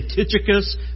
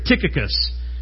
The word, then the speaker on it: Tychicus: I want to say Tychicus, but it's Tychicus. Paul begins here by pointing out the faithfulness of Tychicus in verses 7 and 8.